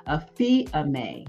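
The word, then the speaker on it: aphiemi (ἀφίημι): So when we're looking at forgiveness from a biblical perspective, the Greek word for forgiveness is, it's pronounced aphiemi, A-P-H-I-E-M-I.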